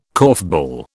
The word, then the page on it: korfball: British English